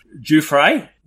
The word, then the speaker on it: Giuffré: So, Remo, Giuffré, welcome to The Common Creative.